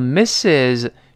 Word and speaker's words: Mrs: Mrs 表示。某某夫人，某某太太，我们注意这个发音啊，读成 Mrs，中间两个哎哎，最后发 z 这个声音，拼写呢就是 m 大写的 m，再加 r s，m r s，我们跟老师读起来，Mrs，Mrs，哎，就是某某夫人或者太太，那。